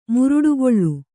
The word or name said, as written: ಮುರುಡುಗಲ್ಲು